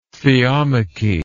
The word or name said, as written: theomachy